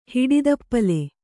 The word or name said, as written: ಹಿಡಿದಪ್ಪಲೆ